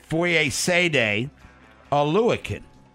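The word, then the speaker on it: Foyesade Oluokun: I got the pronunciation guide on the old flip card. It is Fosade Olakan.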